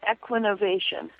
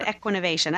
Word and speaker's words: Equinovation: I say it, Equinovation. – Oh, that sounds better than what we did, Helena. – Yeah, so the short E sound, Equinovation. I like Equinovation.